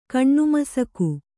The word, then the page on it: ಕಣ್ಣುಮಸಕು: kaṇṇumasaku